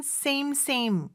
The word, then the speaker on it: same: Things have been same 얘기해도 돼요? – I guess. Yeah. – 뭐, 나쁘진 않죠, 네. 그렇죠? Things have been same. 근데 우리 쌤쌤이라고 하잖아요, 쌤쌤. 쌤쌤 발음은 안 돼요.